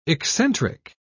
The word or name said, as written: eccentric